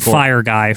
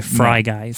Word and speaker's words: Fry Guy: Not Fire Guy, Fry Guy. – Fry Guy. – For some reason. – Fry Guy. – Yeah. – Because it rhymes, Quinn.